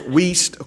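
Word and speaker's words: Wuest: And that's how you pronounce that name. If you're looking at it on the screen, it's W-U-E-S-T, but pronounced Wiest, okay?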